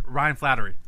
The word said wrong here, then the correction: Flaherty: He pronounced it wrong, though. He said Flattery. – Ryan Flaherty. I know how to say the name, all right? – We've gone over his pronunciations.